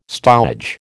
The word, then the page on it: stowage: American English